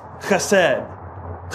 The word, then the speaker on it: chesed: I believe it's pronounced chesed. Chesed. I don't know if that's perfect. If you're a Hebrew scholar, somebody gave me a thumbs up, so we'll take that as true.